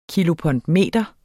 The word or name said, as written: kilopondmeter